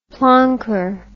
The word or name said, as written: plonker